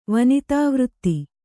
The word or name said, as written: ವನಿತಾವೃತ್ತಿ